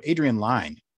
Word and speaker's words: Adrian Lyne: I said it wrong when we were talking about this movie earlier this year, but Adrian Lyne. Adrian Lyne, yeah.